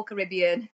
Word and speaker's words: Caribbean: Caribbean. I remember talking about this at school, but yes, it can be either or. I call Caribbean.